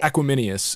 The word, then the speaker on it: Equanimeous: Equanimeous.